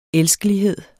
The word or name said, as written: elskelighed